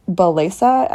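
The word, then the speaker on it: Bellesa: And so I know that a lot of my lady friends prefer a website called Belesa. – What's it called? – Belesa, I believe that's how it's pronounced. It's B-E-L-L-E-S-A.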